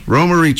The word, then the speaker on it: Romary: We've got Michelle Romerio Trio. Did I say it right? – Romery Trio.